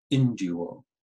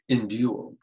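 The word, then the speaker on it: enduo (ἐνδύω): With the word for put on, the verb is enduo, the accent's on that second syllable, enduo, and it means to clothe.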